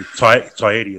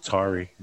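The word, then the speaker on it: Taieri: And he was just trying to say, "Look, it's Tyeti. That's how it's pronounced. It's Maori."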